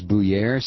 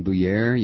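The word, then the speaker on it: Buyer: Apparently, I've been mispronouncing Stephen Byer's name, the guy who Republican from Indiana who chairs the Veterans Affairs Committee in the House. His name is Bouyer, Stephen Bouyer. You pronounce it like that?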